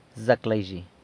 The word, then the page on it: Zac laizhi!: San Lucas Quiaviní